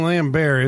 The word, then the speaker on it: Lambert: I'm just not realizing how much Thomas Jane looks like Christopher Lambert. – Yeah. – Right? – Yeah, they have the same squint. – We keep saying Lambert. Is that how you pronounce his name? – He's French. Yes.